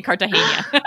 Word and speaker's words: Cartagena: Hotel Cartagena in Cartagena. – Where is it at? – Am I saying it wrong? – No. You're saying it right.